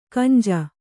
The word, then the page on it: ಕಂಜ: Kannada